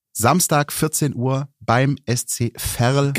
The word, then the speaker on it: Verl: Verl, bitte. – Verl, sorry. – Es wird mit V ausgesprochen wie Vogel. Aha. Und nicht mit W wie Weltuntergang.